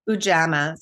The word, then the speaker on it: Ujamaa: It's Ujamaa. Is that how you say it? Ujamaa.